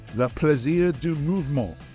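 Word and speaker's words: le plaisir du mouvement: After another fantastic week of European action, lots of controversy, in the opinion of Stuart Barnes, la plaisir du mouvement. Is that correct? Did I say that right, Stuart? – You said it quite beautifully.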